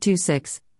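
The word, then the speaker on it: II-VI: We talked about Tuvi, pronounced two six,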